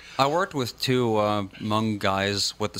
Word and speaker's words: Hmoob: I still want to know how H-M-O-O-B is pronounced Hmong.